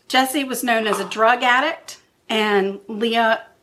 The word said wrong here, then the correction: Lea: not Leah, sorry, I'm sorry. Her name is Lee. It's spelled L-E-A, but pronounced Lee.